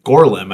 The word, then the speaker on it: Gorlim: And so at one point, Sauron actually captures one of these outlaws named Gorlim, I believe is how you'd say it. – Yeah, that's how it's said.